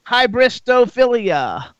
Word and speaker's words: hybristophilia: Let's first talk about hybristophilia. – Yes. – Is that how I pronounce it? – Yes, you said it correctly, and it's with one of your friends.